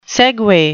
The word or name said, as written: segue